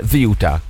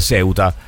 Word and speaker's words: Ceuta: In spagnolo della Spagna si dice Ziuta. – Ziuta. – Se poi lo volete pronunciare in spagnolo ispanoamericano, diciamo così, o italianizzandolo, diventa Ceuta. Però non altro, mi raccomando, non altro. Comunque la pronuncia originale è Ziuta.